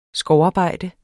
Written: skovarbejde

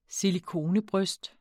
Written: silikonebryst

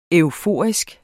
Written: EUFORIEN

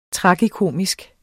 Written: tragikomisk

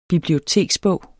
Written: BIBLIOTEKSBOG

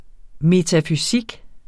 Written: metafysik

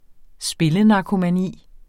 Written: spillenarkomani

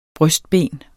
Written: brystben

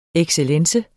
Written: excellence

